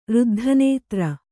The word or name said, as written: ರುದ್ಧನೇತ್ರ